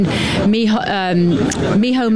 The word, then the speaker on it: Micheál: Micheál Martin, to take the Irish pronunciation here.